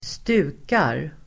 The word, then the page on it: stukar: [²st'u:kar]